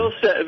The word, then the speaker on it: Vilsack: Vilsack, mm-hmm.